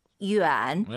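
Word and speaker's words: 윤 (远): 위 안이 아니에요, 여러분. 다시 한번 들어볼게요. – 윤. – 네, 윤. 엔 느낌이 나죠.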